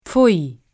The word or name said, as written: pfui